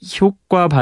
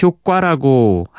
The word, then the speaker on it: 효과: 6397번님이 박창현 아나운서는 효과 발음을 효과라고 하시네요. 어, 보통 아나운서들은 효과라고 하시던데 아, 이렇게 보내주셨는데 둘 다 가능한 발음입니다.